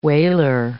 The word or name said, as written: whaler